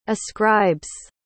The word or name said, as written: ascribes